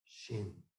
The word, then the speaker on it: xing: I have nature, which is another character in Chinese, but it's pronounced X-I-N-G, shin.